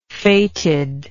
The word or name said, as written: fated